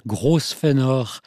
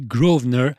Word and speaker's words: Grosvenor: Il a même pu m'arriver de dire Grosvenor en prononçant toutes les lettres de son nom, puisque ça s'écrit Grosvenor, mais je crois bien que ça se dit Grosvenor.